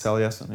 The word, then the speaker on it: Taliesin: I think my favorite, I don't actually know how this is pronounced at all, the Taliesin West House? – Taliesin, yeah. – Taliesin? Mm-hmm. Wait, did you say that already? – No, I didn't say Taliesin, no. – Okay, sorry. But yeah, the Taliesin West House is in Arizona.